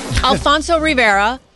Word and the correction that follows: Ribeiro: And we'll just stop you there. Alfonso Ribeiro is the correct pronunciation of that.